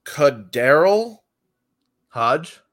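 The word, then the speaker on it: KhaDarel Hodge: I'm going to go with Kadarrell Hodge.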